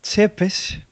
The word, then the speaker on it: Tepes: claro, entonces de ahí viene su nombre, porque Tepes, o parece que se pronuncia Chepes, bueno, ya no sé pronunciarlo, quiere decir empalador.